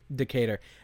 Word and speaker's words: Decatur: But some other accounts that we have with Stephen Decatur. – Decatur. How do you spell that? Or say that? – Decatur.